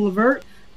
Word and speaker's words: LeVert: The Nets did not make any sense at all, especially that you got rid of LeVert. That's his name, how you pronounce it.